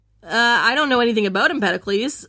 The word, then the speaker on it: Empedocles: Empedocles. – Empedocles? – Empedocles. E-M-P-E-D-O-C-L-E-S. D-O-C-L-E-S. – Empedocles? – No, it's Empedocles.